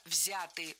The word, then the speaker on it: взяты: Не взяты, а взяты. Ударение в этом слове сохраняется на первом слоге. Он взят, оно взято, они взяты. И только лишь в женском роде ударение переносим на окончание. Она взята.